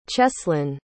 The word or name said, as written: cheslyn